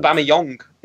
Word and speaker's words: Aubameyang: Aubameyang is another one as well. – Aubameyang, yeah. – That's what they've been saying on BT Sport recently. – Ah, they're probably right, to be fair. Aubameyang has apparently stunned Borussia Dortmund.